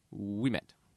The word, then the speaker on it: Ouimet: It's spelled O-U-I-M-E-T, which is interesting to me. You know, Ouimette, but it's pronounced Ouimette. So we met the other day, yes.